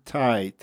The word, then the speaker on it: Tye: Okay, and Martin, your last name is T-Y-E. For those of you who want to look you up on Twitter, Martin Tye, T-Y-E.